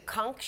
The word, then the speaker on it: conch: Brady even using a conch shell, like rhymes with Gronk, I guess, to summon Gronk out of his first retirement to join him in Tampa Bay. Where's the conch now, is the question.